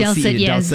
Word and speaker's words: dulcet: I don't know if I'm pronouncing the word correctly. Dulcet. Is that it? – D-U-L-C-E. Dulcet, yes.